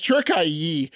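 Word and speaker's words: Türkiye: From now on, Turkey will be known as Turkiye, T-U-R-K-I-Y-E, quote, to better reflect their cultural values, according to President Erdogan.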